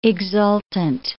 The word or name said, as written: exultant